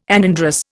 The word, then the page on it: anandrous: Indian